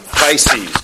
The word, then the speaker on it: Pisces: Pisces, good.